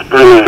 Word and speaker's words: Brunet: Now, I think Brunet is the pronunciation. It's spelled B-R-U-N-E-T. I think that's Brunet. – Brunet, yeah.